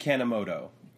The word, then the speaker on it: Kanemoto: Then you have, how do you say it, Kanemoto? – Koji Kanemoto. – Kanemoto.